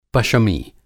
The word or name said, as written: पशमी